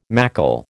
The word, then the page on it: macle: American English